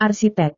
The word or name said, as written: arsitek